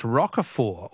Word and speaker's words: Roquefort: The French Roquefort, also with a silent T, is very smelly.